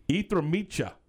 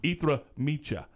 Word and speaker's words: jitrnice: And I apologize if I mispronounced it, but l- let me tell you how it's spelled J I T R N I C E. Now, does that start with an E? no, it doesn't. But that's exactly how it's pronounced, uh, I believe.